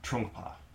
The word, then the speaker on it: Trungpa: But, But I also, one of my longtime teachers, Gordon Beam, also very much respected, Sharum Trungpa, am I saying it right? – Trungpa.